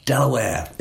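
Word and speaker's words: De La Warr: So on the page, it's D-E-L-A-W-A-R-R. But Delaware is how they pronounce it.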